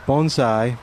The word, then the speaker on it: bonsai: I sort of think of, and I realize now listening to you that I'm not pronouncing it correctly. So the bonsai, which I think now I'm saying it right.